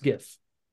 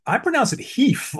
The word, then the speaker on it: GIF: So, anyways, yeah, GIF is pronounced GIF. – Sure. – Oh, okay. G I pronounce it heef, like the Spaniards intended to.